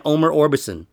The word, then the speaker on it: Omer Orbison: Actually, everybody, this is my friend Omer Orbison.